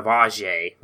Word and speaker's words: Ajay: Well, it's funny because like the whole thing is that he pronounced it AJ as like an Americanized version of Ajay, which is his real Ajay Gali, and he said, you know, he calls himself AJ Gale.